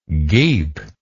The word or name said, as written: geep